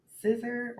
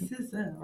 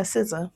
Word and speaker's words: SZA: I never say her name right. I always say SZA or SZA, but it's SZA, right? – Yeah, SZA.